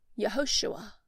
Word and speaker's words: Yehoshua: Yeshua is a variant of Joshua, which in the Hebrew is pronounced Yehoshua.